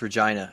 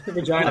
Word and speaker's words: Regina: It's pronounced Regina, and I'm not even kidding. – Regina. – I'm not even kidding. It's Regina. – You pat the vagina. – Like vagina. That's right.